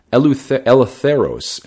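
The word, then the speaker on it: eleutheros (ἐλεύθερος): and it's eleutheros, and that is spelled E-L-E-T-H-E-R-O-S.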